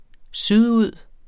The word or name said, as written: sydud